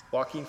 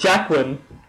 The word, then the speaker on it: Joaquin: Is that how you pronounce his name? – Joaquin Phoenix, yeah. – I always thought it was Jacqueline. – Jacqueline. – Do you pronounce the J or not? – That's his sister's name, Jacqueline.